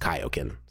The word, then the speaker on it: Kaioken: Um, but then I, I have since, of course, been corrected, and I now know that it is actually supposed to be pronounced Kaioken.